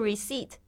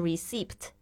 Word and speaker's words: receipt: 特别注意一下这个 receipt，它虽然里面有一个 p，但是 p 不发音，不要念成 receipt，不要念错了。